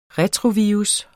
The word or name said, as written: retrovirus